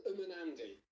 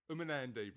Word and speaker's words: Umunandi: Yeah, it's called Umunandi. – Umunandi, right.